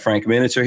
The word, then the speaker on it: Miniter: We need to let people know that we're doing this, that we have Frank Miniter here.